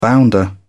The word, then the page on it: bounder: British English